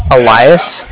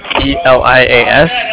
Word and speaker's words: Elias: Elias. E-L-I-A-S. – Wait, how do you spell it? I'm sorry, I missed it. – E-L-I-A-S.